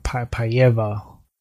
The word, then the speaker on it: Paiva: Paiva.